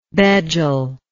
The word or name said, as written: bejel